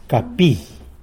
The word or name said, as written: capi